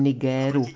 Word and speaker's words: Nigér: jak to ukazuje to povstání v Nigeru. V žádném Nigéru. Povšimněte si, ti, kteří to vyslovují Niger, ti drží vztyčený prostředníček v kapse. To je zvyk ze sovětských dob vyslovovat na západní způsob.